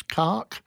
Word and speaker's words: Cocke: My name is James Cocke, and I've always been teased about my last name and mispronounced teachers and everything.